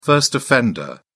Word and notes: first-offender: British English

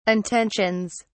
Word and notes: intentions: British English

